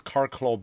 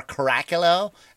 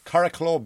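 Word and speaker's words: Curracloe: That whole beach you see, which is supposed to be Normandy, is Carraclough Beach. Or Caracalel, as Americans say when they try to read the signs. Caraclough Beach.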